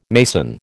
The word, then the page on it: mason: American English